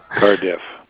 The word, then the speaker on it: Cardiff: Calling from the Bank of Cardiff. That's spelled C-A-R-D-I-F-F. – Cart-T-I-S? – Cardiff. It's like car and different. – Okay. – Yeah. – C-A-R-D-I-S.